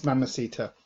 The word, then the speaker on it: Mamacita: Yes, Mamacita.